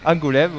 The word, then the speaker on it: Angoulême: Angoulême. – Angoulême, oui.